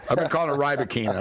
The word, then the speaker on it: Rybakina: So I got Elena Rybakina. – I've been saying Rybakina's name wrong the whole time. I used to think it was Rybakina, and now everybody says Rybakina. So it's Rybakina.